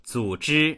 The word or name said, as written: zu3zhi1